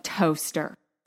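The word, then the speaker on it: Towcester: Police search Ben's car and the swanky new flat he had recently purchased in the nearby town of Toaster. Side note, this town is spelled like Towchester, but it's pronounced Toaster.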